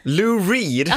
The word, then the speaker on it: Lou Reed: Louis Red. – Va? Hur uttalar du hans namn? – Louis Reed. – Lou Reed? – Aha, Lou Reed.